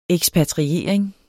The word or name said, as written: ekspatriering